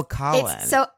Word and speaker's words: Coughlan: Your last name is Irish pronunciation, which not everyone would know. – Oh, Coughlan. – So hilariously, it's actually pronounced Coughlan.